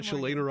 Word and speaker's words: Shalana: And I got Shalana right this time.